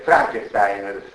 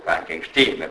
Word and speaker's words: Frankenstein: Frankenstein, che come sapete nel film, gigantesco, geniale, il film di Mel Brooks, Frankenstein Junior, se lo ricordate, Gene Wilder insiste molto Frankenstein, perché dice Frankenstein, pronunciare Frankenstein rimanderebbe troppo all'origine tedesca e quindi avvicinerebbe troppo a Hoffman e al clima romanzo cupo dei tedeschi, anche poi tutto il film di Frankenstein.